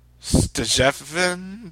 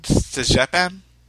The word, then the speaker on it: Stjepan: Uh, his first name is spelled S T J E P A N. So Stjepin Stjepin.